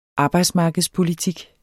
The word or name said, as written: arbejdsmarkedspolitik